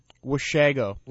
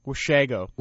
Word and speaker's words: Washago: Washago. – Washago? – Washago.